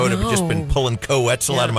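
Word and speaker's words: Koe Wetzel: Rhymes with pretzel. Co-Wetzel.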